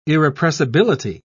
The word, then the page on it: irrepressibility: British English